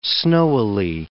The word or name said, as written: snowily